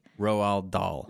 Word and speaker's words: Roald Dahl: Rhoda Dow. Like James and the Giant Peach, Charlie and the Chocolate Factory. – Roald Dahl.